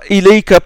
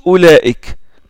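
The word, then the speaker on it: oulaik (أولئك): Ils ont mis ce waou là pour te dire attention, la hamza qui est au début, ça se prononce O. Et ça, c'est pour ça. Autre raison, c'est pour que tu la prononces pas ilayka, par exemple. Si ils enlèvent le waou, la personne risque de la confondre avec ilayka. Hein? Donc, ils ont mis le waou pour que tu lises oulaik.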